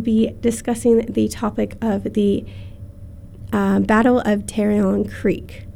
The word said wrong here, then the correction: Terania: Is that how you pronounce it? – Terania. – Terania Creek.